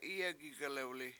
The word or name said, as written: Iye7 gig1lEwli7